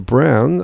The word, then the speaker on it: Braun: B-R-A-U-N. They said it's pronounced Brown. I always thought it was Ari Braun, but the late cantor of the Israeli Armed Forces.